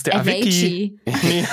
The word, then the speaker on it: Avicii: Avicii.